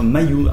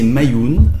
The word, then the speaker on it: Maion: Alors, derrière ce sigle Maillon, Maillon M-A-I-O-N, hautement symbolique, qu'est-ce qui se cache ? – Maillon, en fait, c'est de l'occitan. Ça veut dire Mayoun. En fait, il faudrait le prononcer Mayoun, ça veut dire maison.